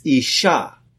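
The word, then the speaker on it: isha (אשה): And the word for woman is isha, which is aleph, shin, he.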